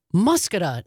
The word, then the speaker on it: Muskoka: And I did not pronounce the town of Muskoka right. I hope I'm getting that right now. Thanks to Robert in West Dallas. And I think, Jane from Madison listening on WMDX.